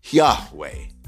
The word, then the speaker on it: Yahweh: I have alighted and chosen uh, uh to use the name Yahweh or Yahweh. That's my preferred option.